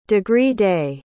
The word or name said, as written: degree-day